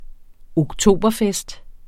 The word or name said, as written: oktoberfest